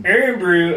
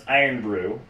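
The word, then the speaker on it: Irn-Bru: Earn Brew apparently is pronounced Iron Brew, which is, I think, Celtic? – It's spelled IRN. Orange line, BRU, orange line.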